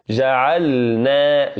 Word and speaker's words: جعلنا: فاحرص ألا تدغم اللام في النون. فلذلك نسمع الكثير يقول جعلنا وجعلنا وجعلنا الليل لباسا وجعلنا، يدغم اللام في النون، ولكن لابد من نطق اللام لوحدها والنون لوحدها، كأن نقول جعلنا جعلنا.